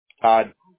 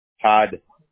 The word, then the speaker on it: Todd: Todd. – Sorry? – Todd. – Todd, can you spell that for me, please? – T-O-D-D.